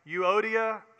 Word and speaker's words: Euodia: and we believe possibly that Euodia and Syntyche, which is, if you don't know how to pronounce those, Euodia and Syntyche, were possibly even part of that group.